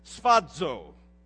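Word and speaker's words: sphazo (σφάζω): That murdered word in the Greek is a word that we pronounce sphazo. Doesn't even sound like murder, but folks, it means to butcher, it means to slaughter, it means to mutilate, and it means to maim violently.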